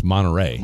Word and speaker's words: Monterey: Am I saying it wrong? Is it Monterey? – No, it's Monterey. – Monterey? – Oh, what did I say? – Monterey. You added an extra R.